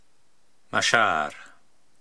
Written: Mashaar

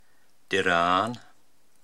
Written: Diraan

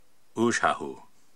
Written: Uuzhahu

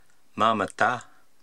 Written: Maamatta

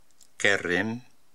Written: Kerrim

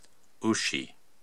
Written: Oushi

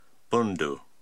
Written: Bundu

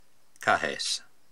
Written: Kahes

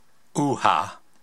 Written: Ouha

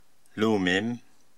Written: Loumim

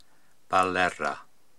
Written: Ballerra

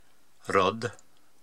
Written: Rodd

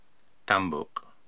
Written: Tambuk

